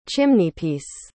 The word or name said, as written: chimneypiece